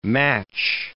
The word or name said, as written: match